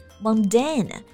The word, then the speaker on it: mundane: Mundane 这个单词有点像摩尔丹、蒙丹，但它其实指的是平凡的、实际的。发音的时候呢，注意我们重音是在第二个音节上，mundane，然后后面的元音是 a 和字母 a 读音是一样的。